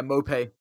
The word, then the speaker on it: Maupay: There's obviously the Neil Maupoy one, which I don't know if I'm saying, am I saying his name right? Brentford striker? – Yeah, Maupay.